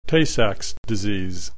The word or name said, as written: Tay Sachs Disease